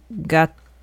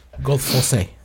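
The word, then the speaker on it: Gattefossé: Gatefaut. Gadafoss? – It's pronounced Flance. – Renee Marie Flance. Gadafoss? I don't know. I didn't take French. – Gadafossé.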